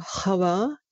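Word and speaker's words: Chava (חוה): Ja, Eva ist kein Eigenname, sondern hebräisch ausgesprochen Chava bedeutet das Leben.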